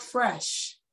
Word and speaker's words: FRSH: Oh, um, it's called Fresh, but it's spelled F R S H.